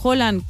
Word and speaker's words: Haaland: Que muchísima gente confunde Haaland y Haaland. Haaland, que es el actor. – Tom Holland. – De Spider-Man y Haaland. Se pronuncian distinto.